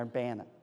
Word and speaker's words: Urbana: it is pronounced Urbana, right, Ohioans? Urbana? Urbana? Yeah. Urbana. When he moved out there, they didn't recognize her call anymore.